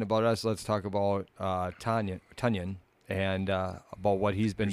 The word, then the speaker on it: Tonyan: It's oh, T-O-N. At the end. It's T-O-N, though. – Y-A-N. – How do you... Tonyan. I'm gonna spell a word. Tell me how you pronounce it. – Okay. – Ton. T-O-N. – Tonyan. – Tonyan. All right. Now you got it.